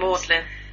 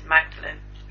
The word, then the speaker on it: Magdalen: Magdalen. – What did I say? – It's Magdalen, which is how it's... I'll tell you this, Sina. It's how it's written, but it's not how it's pronounced.